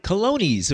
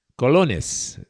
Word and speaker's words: colones: He'll say, why, colonies, of course. Not colones, which is the correct native pronunciation.